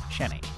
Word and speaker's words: Xeni: A That's how you spell it, but it's pronounced Chenny.